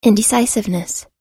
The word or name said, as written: indecisiveness